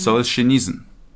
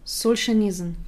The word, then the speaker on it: Solzhenitsyn: Wie spricht man seinen Nachnamen? – Solzhenitsyn. – Solzhenitsyn.